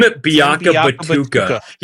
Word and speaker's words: Biakabutuka: Tim Biakabutuka.